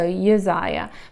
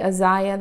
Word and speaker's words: Uzziah: And I've heard him called also Uzziah, but I think I'm going to say Uzziah. That's how the phonetic markings in my Good Study Bible pronounce this.